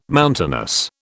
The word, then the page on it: mountainous: British English